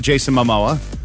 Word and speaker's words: Jason Momoa: We know Jason Momoa. Jason Mimosa. – Thank you. Say his name correctly.